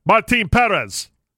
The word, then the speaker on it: Martin Perez: Martin Perez. That's how you say his name. – I'm looking at the name right near here. It says Martin Perez. – No, that's not how you say it.